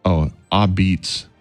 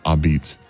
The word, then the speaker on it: apizza: They're calling it a pizza, pronounced, a-beats. It looks like A-P-I-Z-Z-A, but it's a-beats.